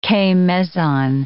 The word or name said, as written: K-meson